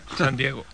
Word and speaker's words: San Diego: San Diego.